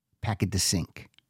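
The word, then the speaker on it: Paquet de Cinq: Just so you know how to spell that, it's P-A-Q-U-E-T, one word, capital D-E, second word, Sync, C-I-N-Q, Packet to Sync.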